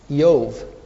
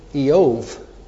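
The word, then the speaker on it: Iyov: By the way, if you were Hebrew and you come across the word Job in your Hebrew Bible, you would pronounce it this way, Yov, with a little E and O together, Eov with a V. Yov.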